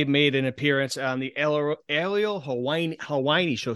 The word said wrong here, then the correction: Helwani: Excuse me, Hawaii. I can't pronounce it, I guess. We'll take two. – Okay, Aerial Helwani. – Aerial Helwani. There you go.